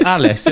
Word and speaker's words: Alyce: Because you're that's not even how it's pronounced either. It'd be Alice. It was like A-L-Y-C-E.